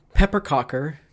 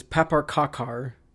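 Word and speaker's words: pepparkakor: We're going to have some pepperkakor cookies, which is pepperkakor. Pepper, how do you say it? – Pepperkakor.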